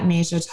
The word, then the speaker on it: Najiah: I'm on Instagram, and my at name is at Najiah Taught You, which is the second half of my name, but it's spelled N-A-J-I-A-H Taught You.